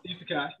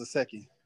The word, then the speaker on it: isekai: Isekai. You're right. – Isekai. I thought it was a Seki.